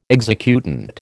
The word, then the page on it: executant: American English